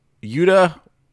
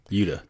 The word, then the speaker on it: Yuta: I go harder on the T. I'm just talking about the soft T there. Yuta.